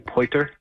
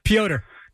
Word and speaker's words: Pyotr: I really like, how do you say his name? Poiter? – Pyotr.